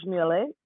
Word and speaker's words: Méliès: a Trip to the Moon, directed by Georges Méliès, Mm-hmm.